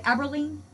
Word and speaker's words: Aberline: Am I pronouncing it right? Aberline? – Yep, that's right.